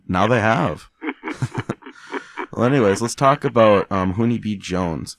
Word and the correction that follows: Junie B. Jones: Is that how it's pronounced, by the way? – Junie B. Jones. Okay.